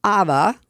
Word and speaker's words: AVA: And AVA is spelled just A-V-A. Nice and simple. – A-V as in Victor. – V as in Victor, A-V-A. Yes. – Not ABBA as in Dancing Queen.